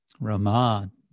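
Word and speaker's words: Ramā: Rama Devi is Lakshmi with the long A, rama.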